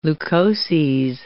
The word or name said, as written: leukoses